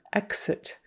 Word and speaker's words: exit: We usually say it with more of a GZ. Exit. Exit. I don't know why. I just know we do. I know there are some X's that we pronounce with a KS, and it's usually at the end of the word. For instance, fax. Send me a fax. That's KS. That X is KS sounding. Facts. Um, exit, exam, examine. Those are all GZ.